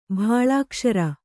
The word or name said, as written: ಭಾಳಾಕ್ಷರ